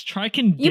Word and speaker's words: Ticonderoga: It's Ticonderoga.